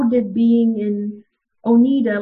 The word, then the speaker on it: Oneida: And then you leave that kind of context and you come to Oneida. Is that how you say it, Maria? – Yes. – Oh, yes. – So you come to Oneida and you're like in the middle of nowhere.